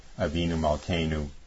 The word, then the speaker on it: Avinu Malkeinu: It's spelled A-V-I-N-U, new word, M-A-L-K-E-I-N-U, Avinu Malkeinu.